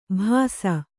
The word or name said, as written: ಭಾಸ